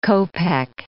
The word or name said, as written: kopeck